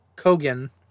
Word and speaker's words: Keoghan: Barry Keoghan. – Barry Keoghan.